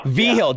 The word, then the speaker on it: Vigil: And the first person I'd really like to ask you about is Dr. Joe Vigil. I believe I pronounced that correct, I hope. – Vigil.